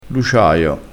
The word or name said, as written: luciaio